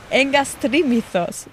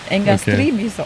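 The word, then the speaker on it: Engastrimithos (εγγαστρίμυθος): Und zwar, ich hoffe, ich kann das jetzt richtig sagen, Engastrimithos. Und das ist der Bauchredner. Das finde ich ist irgendwie so lustig, weil... – Engastrimithos? – Ja, Engastrimithos. Also auf dem I ist die Betonung. Strimithos. Engastrimithos.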